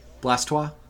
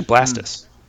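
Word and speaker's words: Blastoise: It's actually Blastus. It's pronounced okay. Blastus. Yeah, because it's like, like a blast of water and a tortoise.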